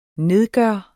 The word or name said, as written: nedgøre